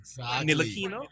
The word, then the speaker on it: Ntilikina: Frank Ntilikina, I hope I'm pronouncing him correct.